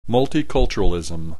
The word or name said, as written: multiculturalism